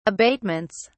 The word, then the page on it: abatements: British English